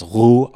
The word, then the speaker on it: Roux: That's Roux, R-O-U-X. I think that's how you say it.